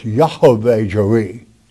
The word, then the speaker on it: Yahweh Jireh: I like to pronounce it correctly, though. It's Yahweh Jireh.